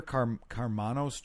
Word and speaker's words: Karmanos: So, Peter Karmanos Jr., am I pronouncing that? – Yes, yeah.